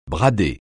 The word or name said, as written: brader